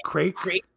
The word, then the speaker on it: Craic: His name, I think it's supposed to be Crack Boy Mental. I believe that's what it's supposed to be, but it's spelled C-R-A-I-C. – Okay, so that, yep, Crack Boy.